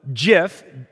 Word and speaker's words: GIF: So GIF, G-I-F, that's by the way how you pronounce it, stands for graphic interface format.